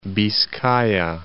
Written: Biscaya

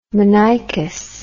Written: monoicous

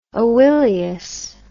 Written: oileus

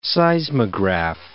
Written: seismograph

